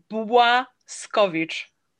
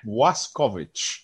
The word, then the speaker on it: Błaskowicz: Yeah, perfect. Bang on. So that's, repeat that again. Go for it. – Błaskowicz. – Yeah, perfect. – Well, there we go. My Polish pronunciation has gone up a level, thanks to your careful tutelage.